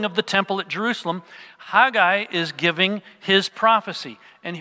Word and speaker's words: Haggai: Many of you pronounce it Haggai. I have no idea where that pronunciation came from, but that's the more common pronunciation in English, I guess.